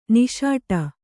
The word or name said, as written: ನಿಸುಟು